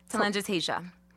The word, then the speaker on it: telangiectasia: Telangiectasia.